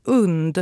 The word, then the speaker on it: UND: Vi på radion har blivit informerade om att det ska uttalas UND. Mm. Tänk hund minus H.